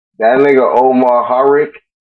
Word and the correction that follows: Omari Hardwick: Is that how you say it? Harrick? – Omari Hardwick or some shit like that? – Yeah.